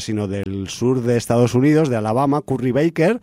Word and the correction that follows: Barker: Todo el mundo lo pronuncia mal. Mira el apellido escrito. Curry Barker. – Barker. – Todo sí. el mundo se come la R sí. – y le llama Baker.